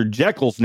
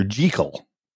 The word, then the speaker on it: Jekyll: The only version where Dr. Jekyll's name is pronounced correctly, Dr. Jekyll.